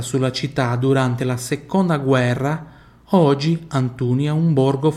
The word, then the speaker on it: guerra: olha como é que eu falo guerra, se escreve igual ao português, mas se fala diferente, guerra, guerra, tem o som do U ali, tá?